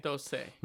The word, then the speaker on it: doce: Is it Doce? – Doce. – La Doce de Atlanta.